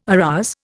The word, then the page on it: arouse: Indian